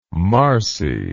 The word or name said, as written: marcie